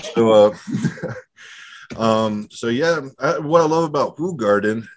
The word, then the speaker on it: Hoegaarden: I had some Hoegaarden, and speaking of Hoegaarden, is that the proper pronunciation of Hoegaarden? I know you're in Belgium, and it's a Belgian beer, so is it Hoegaarden? – Hoegaarden, there we go.